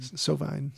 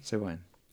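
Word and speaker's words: Sovine: How do you pronounce his name? – Sauvin. – Sauvin. – Yeah. Well, that's how Tom Waits says it when he does a cover of it.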